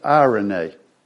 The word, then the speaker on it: eirene: It's, you see how it's spelled in your notes, E-I-R-E-N-E. You've got the Strong's number, and it's pronounced irene.